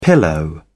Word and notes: pillow: British English

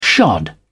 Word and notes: shod: British English

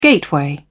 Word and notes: gateway: British English

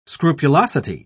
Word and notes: scrupulosity: British English

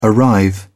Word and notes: arrive: British English

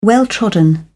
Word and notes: well trodden: British English

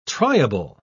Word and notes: triable: British English

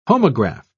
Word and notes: homograft: British English

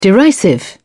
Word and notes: derisive: British English

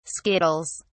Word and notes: skittles: British English

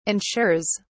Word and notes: ensures: British English

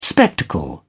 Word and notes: spectacle: British English

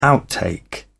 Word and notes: outtake: British English

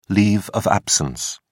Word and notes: leave of absence: British English

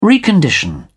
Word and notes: recondition: British English

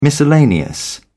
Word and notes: miscellaneous: British English

